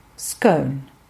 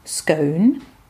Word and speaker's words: scone: Über die korrekte Aussprache des Wortes ist die Nation seit jeher gespalten. Je nach geografischer Herkunft und oder gesellschaftlicher Position Scone oder Scone.